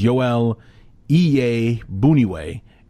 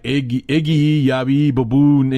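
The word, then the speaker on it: Joel Iyiegbuniwe: And believe me when I tell you, it is not spelled like that. It is not spelled. You would not get Iye Buniwe looking at that name. You would be like Iggy Yabi Baboon and something like that.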